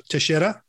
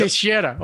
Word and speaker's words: Teixeira: Teixeira, am I pronouncing that right? – Uh-huh. Teixeira. – Yep. Teixeira.